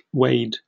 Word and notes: weighed: British English

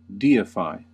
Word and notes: deify: American English

